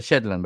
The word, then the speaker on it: Shetland: Me da mucha risa porque cada vez que escucho la música de Shirland, de Shetland, ¿cómo se pronuncia? Shetland, ¿verdad? No, Shetland, no Shetland. no, Shetland, Shetland.